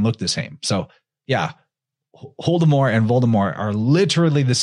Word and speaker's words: Voldemort: But that Voldemort, and actually in the audiobook, the reader doesn't even say the T, it's a silent T. So he says Voldemort.